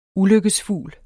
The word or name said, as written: ulykkesfugl